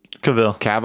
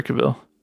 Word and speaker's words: Cavill: Cavill. I think it's Henry Cavill.